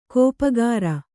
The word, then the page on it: ಗಪ್ಪುಗಾರು: gappu gāru